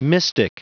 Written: mystic

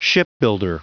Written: shipbuilder

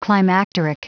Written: climacteric

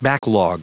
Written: backlog

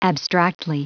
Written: abstractly

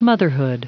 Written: motherhood

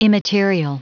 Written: immaterial

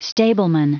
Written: stableman

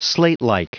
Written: slatelike

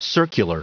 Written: circular

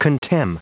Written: contemn

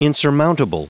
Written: insurmountable

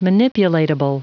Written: manipulatable

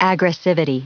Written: aggressivity

